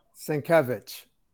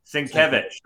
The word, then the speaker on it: Sienkiewicz: Sienkiewicz. – Sienkiewicz.